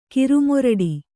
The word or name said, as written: ಕಿರುಮೊರಡಿ